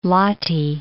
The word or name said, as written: Lahti